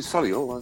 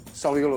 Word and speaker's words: Solihull: I don't even pronounce the H. I say Solihull.